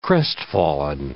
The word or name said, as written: crestfallen